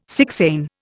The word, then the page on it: sixain: Indian